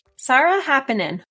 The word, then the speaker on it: Saara Haapanen: Saara Haapanen.